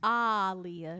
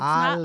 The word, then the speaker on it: Aaliyah: It is Aaliyah. It's Aaliyah. Not Aaliyah like we be doing. You know, we lazy. – Long A. Muhammad Ali.